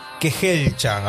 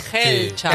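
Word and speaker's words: Kejelcha: El autor fue Yomif Kehelcha, ahora lo dije bien. – Ahí está. – Kehelcha.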